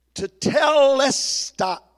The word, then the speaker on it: tetelestai: This is tetelestai.